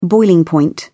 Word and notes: boiling point: British English

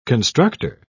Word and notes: constructer: British English

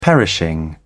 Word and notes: perishing: British English

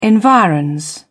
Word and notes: environs: British English

